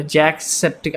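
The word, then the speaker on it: Jacksepticeye: Jack Septic. I don't, I can't pronounce that name. Like, पता नहीं, I can't spell that. Yeah, Septiceye. Yeah, तुमने तुमने ठीक pronounce किया था.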